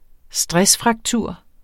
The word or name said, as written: stressfraktur